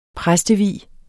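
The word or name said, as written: præstevielse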